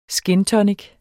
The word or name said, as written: skintonic